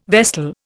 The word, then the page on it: vestal: Indian